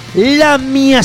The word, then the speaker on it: Lamia: Lamia Satánica. Un compendio. Pero Lamia no es un verbo en pasado, ¿eh? O sea, porque habría que ponerle un acento en la I.